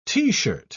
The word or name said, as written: t-shirt